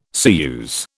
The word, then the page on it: ceruse: British English